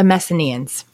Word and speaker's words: Messenians: They go after, I'm going to say it wrong if I don't look at it, the Messenians.